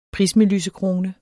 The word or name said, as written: PRISMELYSEKRONE